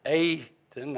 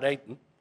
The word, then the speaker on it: Aten: It's a long A, you pronounce it Aten, not Aten.